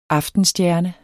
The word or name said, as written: Hesperis